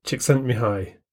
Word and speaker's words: Csikszentmihalyi: But I've been assured that his name is Mihai Csikszentmihalyi. It's a Hungarian name, and it's spelt C-S-I-K-S-Z-E-N-T-M-I-H-A-L-Y-I. Csikszentmihalyi, I think.